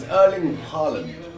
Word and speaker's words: Erling Haaland: So it's Erling Haaland. – That's what I said. – There you go. – So Haaland. – Erling Haaland.